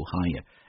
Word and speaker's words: hangar: 注意这个单词在读的时候那个 G 不发音，直接读 hangar at Mojave Air and Space Port。